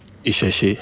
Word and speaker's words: Isese: It's spelled, you'll help me. It's spelled ISESE. How do you pronounce that? – Isheshe.